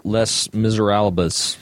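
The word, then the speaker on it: Les Misérables: In the novel Les Miserables. Is that how you say that? – Wow. Yes, it's actually absolutely how you say it.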